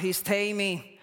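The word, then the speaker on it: histemi (ἵστημι): Taking it from the Blue Bible, stand from the Greek word is pronounced histemi, and as a given, and the meaning of it, it says continue and safe and sound.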